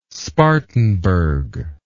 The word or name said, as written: Spartanburg